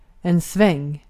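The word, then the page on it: sväng: Swedish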